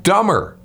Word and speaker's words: Dummer: Dumber, but it's not. No. It's spelled D-U-M-M-E-R, dumber.